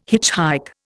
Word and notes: hitchhike: Indian

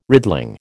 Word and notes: riddling: American English

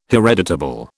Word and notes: hereditable: British English

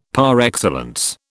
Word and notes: par excellence: British English

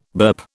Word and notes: burp: British English

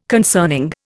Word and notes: concerning: Indian

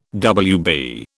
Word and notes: Wb: British English

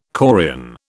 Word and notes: chorion: British English

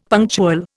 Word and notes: punctual: Indian

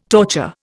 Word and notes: torture: Indian

